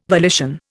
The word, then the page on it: volition: Indian